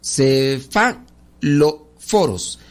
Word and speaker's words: cefalóforos: Los cefalóforos, los cefaloros, ándele, No, los cefalóforos los cefalóforos. ver, es una palabra griega que, no, no está difícil de pronunciar.